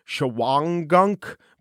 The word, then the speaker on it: Shawangunk: It's spelled Shawangunk, but it's pronounced Shawangunk by the locals.